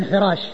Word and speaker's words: حراش: من قال خراش يعني بالخاء بدل الحاء في وارد ربعي ربعي بن حراش هو بالحاء المهملة وليس بالخاء المعجمة